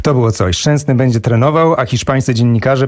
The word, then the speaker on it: Szczęsny: Szczęsny makes a brilliant!